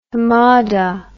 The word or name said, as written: hamada